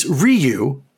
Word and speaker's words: Ryu: It's Ryu with an accent on the second syllable, though you should try to say it as one syllable.